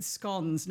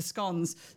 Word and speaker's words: scone: Now, what I love about it is she's in brackets put after scones, stones, so that I'll pronounce it scone. We've had this argument before on the show. It's a scone for me.